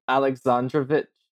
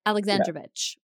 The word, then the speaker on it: Alexandrovich: Oh, I don't even know how to pronounce his last name really. – Ex- Alex, Alex, Alexandrovich. – That was it, Alexandrovich.